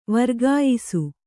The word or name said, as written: ವರ್ಗಾಯಿಸು